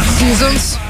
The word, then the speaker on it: Seasons: 72 Sessions, Seasons. Я так думаю про сесію, мені постійно ставлять на вид, що я неправильно вимовляю це слово, тому...